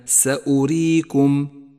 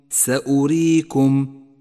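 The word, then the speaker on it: سأوريكم: لاحظ الواو الرمادية في كلمة سأريكم سأريكم، لونت بالرمادي لأنها تكتب ولا تلفظ، وهي من زيادات الرسم العثماني.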